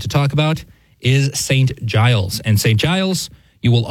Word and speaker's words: Giles: In French, I think it would be Saint Gilles, I think, is how it would be pronounced, but Giles in English.